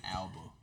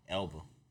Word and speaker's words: Elba: Elba. – I did. – Yours like more like Alba. – Elba.